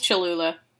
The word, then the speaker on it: Cholula: Well, because in the DJ Khaled interview, I think it was the second sauce was the hot sauce Chalupa or whatever, and I'm mispronouncing it probably. – Cholula? – Yeah. – But I think it's Cholula.